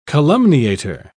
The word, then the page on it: calumniator: British English